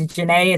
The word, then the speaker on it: Ja'net: And then on Instagram, my name is Janae. It's spelled like Janet.